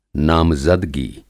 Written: नामज़दगी

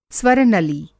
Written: स्वर-नली

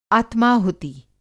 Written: आत्माहुति

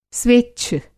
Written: स्वेच्छ

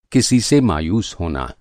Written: मायूस

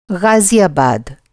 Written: ग़ाज़ियाबाद

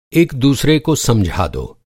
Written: एक दूसरे

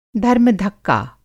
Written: धर्म धक्का

